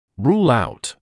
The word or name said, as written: rule out